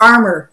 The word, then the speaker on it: Armour: Most people are like, when they pronounce my name often, it's like, Rhonda Armour. I'm like, no, Armour. It's not fancy. It's just Armour.